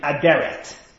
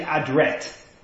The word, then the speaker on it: Adret: Interestingly, in Hebrew, they always say Ben or Ibn Aderet. However, the historians tend to say that it's actually Adret. I don't know why. It's not a Muslim name, Adret, I don't know, but Adret is the technically correct pronunciation, they said.